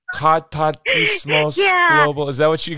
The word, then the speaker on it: Katartismos: Aloha, Faith McDonnell from Cartatismos Global. Did I say that right? Cartatismos.